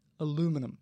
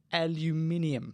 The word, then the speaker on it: aluminium: So here in the United States, I don't know if you knew this, ladies and gentlemen, boys and girls, but here in the United States, the Americans say aluminum. – Because it's correct. – And in Australia, they say aluminium.